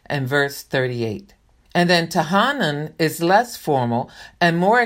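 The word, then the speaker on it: tahanun: So the two nouns, which I believe are pronounced Tahena and Tahanan, are translated supplication.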